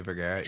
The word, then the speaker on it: Vergara: Is that how you pronounce her last name? – Sofia Vergara, yeah.